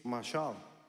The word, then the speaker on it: mashal: The Hebrew word is pronounced in a different way. It's mashal.